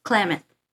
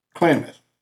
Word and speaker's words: Klamath: Here's some screams in 1993 from, am I saying that right? Klamath? – Klamath. – Klamath.